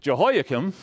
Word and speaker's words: Jehoiakim: Now, the reason I bring this up is because the king of Judah, the king of Jerusalem, Jehoiakim, once again, I may be mispronouncing that. Pastor Mark isn't here to correct me, or Pastor Doug. – No, you're right, because that's how he says it.